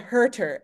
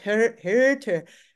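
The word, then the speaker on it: Hürter: Too Big for a Single Mind, and the author's last name is spelled Herter, H-U with an umlaut, Herter, Your turn. – Herter, but umlaut over the U.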